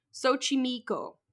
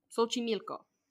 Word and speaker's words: Xochimilco: Is located in a borough of Mexico City called, I had to write out the phonetics of this one, Xochimilco. Xochimilco.